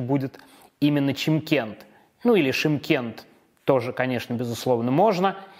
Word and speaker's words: Шымкент: Город Шимкент на юге Казахстана. В советское время его называли Чимкент – во время независимости немножко изменили произношение, чтобы это стало более по-казахски.